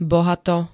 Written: bohato

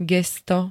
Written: gesto

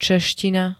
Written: čeština

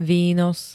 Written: výnos

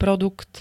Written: produkt

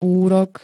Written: úrok

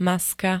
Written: maska

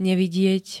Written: nevidieť

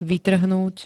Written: vytrhnúť

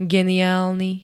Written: geniálny

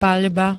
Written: paľba

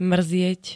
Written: mrzieť

